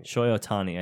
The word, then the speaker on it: Shohei Ohtani: His name is Shohei Ohtani. Shohei Ohtani, I think, is how you say it.